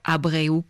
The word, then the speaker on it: Abreu: le maestro Abreu, comme vous le prononcez si bien, puisque vous êtes entre autres hispanophone, Vincent Agrèche.